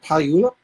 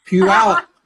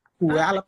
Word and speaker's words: Puyallup: How do you say, is it Puyallup? – Puyallup. – No, Puyallup. There we go. Puyallup. – How you say it better? Say it again. – I said Puyallup. Puyallup.